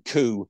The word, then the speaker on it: coup: And we have to talk, I feel like we have to mention the cup. What was the cup and how did it come about? – In English pronunciation, coup is how you say it.